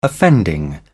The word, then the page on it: offending: British English